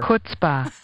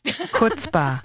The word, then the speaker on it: chutzpah: C-H-U-T-Z-P-A. It's actually closer to chutzpah than it is chutzpah.